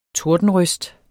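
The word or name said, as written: TORDENRØST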